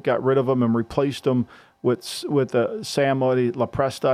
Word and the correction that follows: LaPorta: How do you say that last name? – Sam LaPorta. – LaPorta, right.